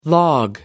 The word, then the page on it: log off: British English